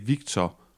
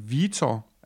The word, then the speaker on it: Vitor: Jeg er jo ikke, øh, vi er jo ingen af os er brasilianske eksperter, men, men apropos brasiliansk udtale, så er det i hvert fald ikke Victor Rodrigo. Det, øh, øh, vi, skal, vi skal nærmere over i Vitor Altså uden set øh,